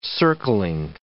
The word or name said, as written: circling